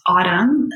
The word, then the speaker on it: Avtvmn: And me personally at Autumn, spelled with V's instead of U's. My middle name is Varia. So it's AVTVMN.